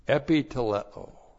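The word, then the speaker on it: epiteleō: Epiteleo. E-P-I-T-E-L-E. Long O. Epiteleo.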